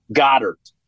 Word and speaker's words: Goedert: Brown, with Devontae Smith, with Dallas Goedert, I always say his Goddard. – Name wrong. – Goddard. – Goddard.